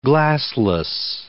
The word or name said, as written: glassless